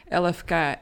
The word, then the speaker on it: LFK: LFK spreek je natuurlijk uit als drie losse letters en liefke is een woord.